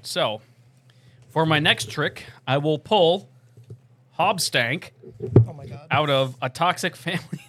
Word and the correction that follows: Hoobastank: You did not just pronounce Hoobastank like Hobstank? – I can't remember who the fuck Hoobastank is.